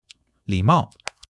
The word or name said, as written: li3 mao4